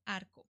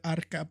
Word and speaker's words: Arco: Santa Juana de Arco. – De Arco. I would have said Arca, but that's why we have you here.